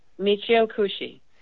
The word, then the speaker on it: Michio Kushi: Michio Kushi.